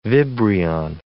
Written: vibrion

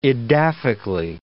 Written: edaphically

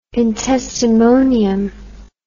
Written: intestimonium

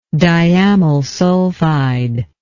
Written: diamylsulfide

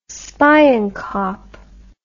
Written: spionkop

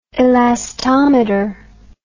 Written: elastometer

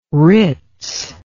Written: Ritz

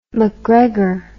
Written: macgregor

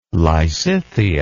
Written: lysithea

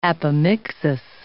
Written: apomixis

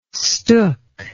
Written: stook

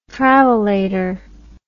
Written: travolator

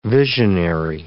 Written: visionary

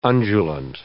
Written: undulant

